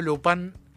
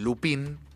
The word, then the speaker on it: Lupin: Pero bueno, yo Lupin, o Lupin, sí. – digo Lupin porque ya me. Como es una serie francesa, cada vez que dicen Lupin, que es como lo decimos nosotros, ellos dicen Lupin.